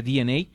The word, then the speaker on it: DeNA: bueno, tienen una alianza en específico con la compañía llamada DNA, que se escribe Dena, pero se pronuncia DNA, que es japonesa, que tiene mucha prominencia en esto de los juegos móviles.